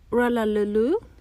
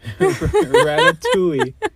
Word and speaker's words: Ratatouille: Because it's French, so you don't pronounce the L's. – Ralalulu. – Ratatouille.